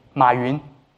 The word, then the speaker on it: Ma Yun: Or more appropriately, Ma Yun. That's the right way to pronounce his name.